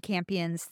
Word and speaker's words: Campion: Her first big breakout was playing an angel in Jade Campion's The Piano, or Champions? How do you say Campion. – Her name? Campion in The Piano.